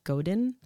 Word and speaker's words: Godin: So the first one is by, and I might say the last name wrong, it's Seth Godin.